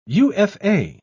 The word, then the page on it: Ufa: British English